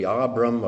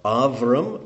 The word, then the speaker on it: Avram: It should be pronounced with a V instead of a B. Avram or Avram, which became Abraham.